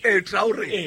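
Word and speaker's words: Traoré: Traore. That's how you pronounce it. Traore, by Kitsa, and they kicked out.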